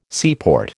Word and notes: seaport: American English

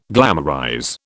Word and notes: glamorize: British English

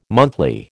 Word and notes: monthly: American English

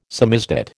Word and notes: samizdat: American English

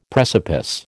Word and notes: precipice: American English

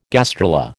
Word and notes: gastrula: American English

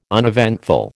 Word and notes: uneventful: American English

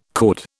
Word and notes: cord: British English

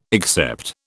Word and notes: except: British English

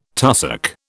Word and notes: tussock: British English

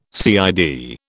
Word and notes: CID: British English

